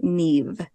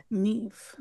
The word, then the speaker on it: Niamh: Chloe on Twitter let us know that it is pronounced Neve. Neve. Neve. Like Neve on Catfish.